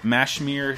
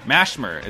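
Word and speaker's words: Mashymre: The commander of this ship, Mashmir Shella... Mashmir is how to pronounce it, I always forget.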